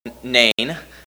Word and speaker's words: Nain: There is actually still a village called Nain, And the Arabs renamed it to N-E-I-N instead of N-A-I-N, but the same pronunciation.